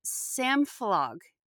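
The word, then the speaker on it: samfélag: So we're going to start with an Icelandic concept that Jocelyn's using, and I'm going to try to say this right, so correct me if I don't again, but it's samflog. Is that right? – Yes, so samflog means, it's an Icelandic word meaning community or a place of community gathering.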